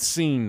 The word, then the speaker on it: Cine: So we're going to go with Seen now. That's as of now. – All right. As of now, he's Lewis Seen.